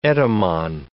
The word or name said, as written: etymon